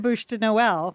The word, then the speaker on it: Bûche de Noël: I'll just go buy the Bûche de Noël. – Yeah. – Am I pronouncing that correctly? – You got that. Spot on. Spot on.